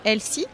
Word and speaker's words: Elsy: Euh, je suis la présidente de Elsie. Ça s'écrit E L S Y. Et vous l'aurez compris, c'est un jeu de mots avec l'anglais Elsie ».